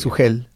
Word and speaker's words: Suhel: Suhel. La H como... Bueno, no lo estabas pronunciando mal, porque en el español en realidad nuestra H es muda, es verdad.